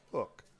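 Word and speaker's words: Huk: I say Hook because it's H-U-K, but it's pronounced Hook.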